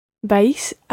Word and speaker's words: vais: It's almost like one syllable. You're pronouncing it ever so slightly as two syllables there, vais. But run them together and just say vais.